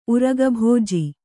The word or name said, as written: ಉರಗಭೋಜಿ